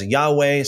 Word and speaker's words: Yahweh: The Tetragrammaton, sometimes pronounced as Yahweh, sometimes pronounced as Yahuwah. Or there's other pronunciations that people believe that it could be as well.